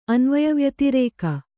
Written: ಅನ್ವಯವ್ಯತಿರೇಕ

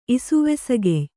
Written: ಇಸುವೆಸಗೆಯ್

